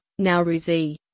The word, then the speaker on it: Nowruz: Nowruz, Persian, pronounced Nowruzi, lit.